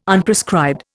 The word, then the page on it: Unprescribed: Indian